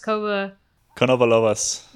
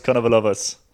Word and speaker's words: Konovalovas: One of the ones that makes me the most sad, because Baltic, is Ignatius, I'm not saying it right. Ignatius Konovalovas. – You nailed it. – Ignatius Konovalovas.